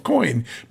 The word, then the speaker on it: Coign: Joining me right now is the founder and CEO of Coin. By the way, that is spelled C-O-I-G-N, just so you know.